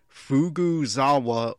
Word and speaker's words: Fukuzawa: And now I'm trying to be more emphatic right there, because last time re-listening, it sounded like I was saying Fukuzawa or Fukugawa. So Fukuzawa. I regret this mispronunciation.